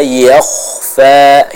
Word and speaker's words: yakhfa: Innahu ya'lamul jahra wa ma yakhfa yakhfa. Ya. Jadi huruf kho dibaca dengan tebal, bukan yakhfa yakhfa, tapi yakh yakhfa yakhfa, seperti itu.